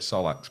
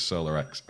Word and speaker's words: SolaX: Yeah, I was saying it wrong to start with, apparently. I've been corrected, I was calling it Solax, but apparently it's Solar X, I don't know.